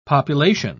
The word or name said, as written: population